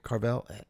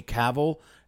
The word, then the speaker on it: Cavill: The Superman guy, Henry Cavill? – Henry Cavill.